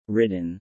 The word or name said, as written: Ridden